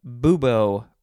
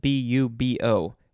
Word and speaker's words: bubo: Next we have bubo or bubo. It is spelled B U B O.